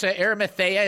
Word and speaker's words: Arimathea: Joseph, and actually it's Arimathea is one of the ways that we'll pronounce it, at least in Michigan, we'll pronounce it that way.